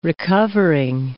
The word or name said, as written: recovering